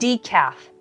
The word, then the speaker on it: decaf: 日本ではよくデカフェという言葉を使うのですが聞いたことあるでしょうかでも英語の発音は全然違います。一緒に練習してみましょう。 Decaf